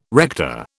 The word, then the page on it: rector: British English